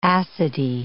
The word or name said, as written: acidy